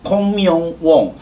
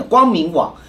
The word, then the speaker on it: 光明网: 北韩有自己一个叫做空明网，好像是这样发音的，光明网。